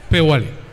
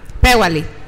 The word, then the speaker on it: Peuali: Peguali es P-E-U-A-L-I. Tlali, como suena, T-L-A-L-I. Eh, pronúncialo bien, Nantli. Peguali. – Peguali.